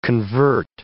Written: convert

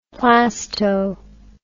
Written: plasto

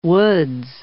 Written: woods

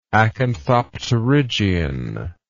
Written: acanthopterygian

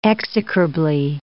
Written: execrably

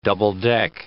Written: double-deck

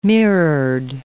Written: mirrored

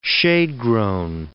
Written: shade-grown

